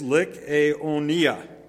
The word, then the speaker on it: Lycaonia: this is a tricky pronunciation. It's five syllables. I looked this up, okay? It's Lycaonia. To get the emphasis on the right place.